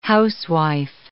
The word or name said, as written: housewife